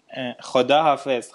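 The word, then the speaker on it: Khoda hafiz (خداحافظ): Khoda hafiz, khoda nigahdar.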